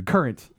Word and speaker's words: Courant: No, it's spelled with an A at the end. – Yeah, it's current. – Is it? – Yeah. – You'd spell it, you would pronounce that current? – Yes. – Yeah. – Okay. – Like the jam. – Yep. – Like the... – like the fruit.